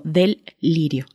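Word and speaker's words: Ediciones Del Lirio: Con Conaculta y Ediciones Delirio tuvieron no delirio, sino del lirio.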